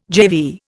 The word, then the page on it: GeV: Indian